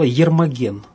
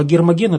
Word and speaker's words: Гермоген: А в произношении того времени чаще звучало Ермоген. Вот отсюда это имя. Ну, а Гермоген – это как вариант того же имени.